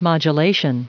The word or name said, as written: modulation